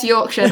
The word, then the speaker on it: Yorkshire: Like right now, Kate's joining us in the live chat, and she's in Yorkshire, I believe is how you pronounce it. I'm trying to say it right. – Yes, Yorkshire.